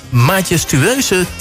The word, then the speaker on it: majestueuze: De hoofdrolspeler in dit nummer draagt een majestueuze titel. Zeg ik het goed? Ja, volgens mij wel. De hoofdrolspeler in dit nummer draagt een majestueuze titel.